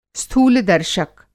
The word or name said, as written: स्थूल-दर्शक